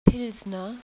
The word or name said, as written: Pilsner